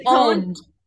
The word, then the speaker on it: pwned: Pwned. Owned only with a P.